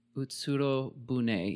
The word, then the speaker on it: Utsurobune: And it's commonly referred to as, Utsurobune. Is that right? – Sounds right. How's it spelled? – U-T-S-U-R-O and then B-U-N-E. – Utsurobune. Yeah, that's cool. That should be right. Yep. – Awesome. So I said it right.